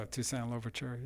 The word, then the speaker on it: Toussaint Louverture: Oh, Toussaint Louverture, yeah.